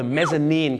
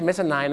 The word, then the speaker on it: mezzanine: mezzanine ครับผมนั่นแหละฮะ mezzanine ไม่ใช่ mezzanine นะครับ mezzanine เหตุผลที่เขาต้องออกเสียงว่า nine เนี่ยเพราะถ้าไปบอก mezzanine เดี๋ยวมันนึกว่าเป็นชั้น 9เดี๋ยวจะทับกันได้